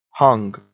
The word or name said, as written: hung